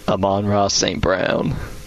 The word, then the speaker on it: Amon-Ra St. Brown: Amon-Ra St. Brown. – Amon-Ra St. Brown, that's his name? – Yeah.